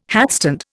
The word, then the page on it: hatstand: Indian